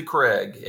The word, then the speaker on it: Craig: I had one of the more memorable meals I've ever had in many, many years at the, I guess we pronounce it the Craig. – It is the Craig, and it's a Gaelic Scottish term meaning a rock, basically.